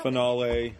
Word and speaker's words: finale: No, like they do mention canceled, but then they also keep mentioning 616 final. – What's that? Finale. What, I don't know. – Fragile. – Finale. There we go. – Proper pronunciation.